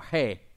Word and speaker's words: He: Ha or he, excuse me, H-E-H was how we would pronounce it.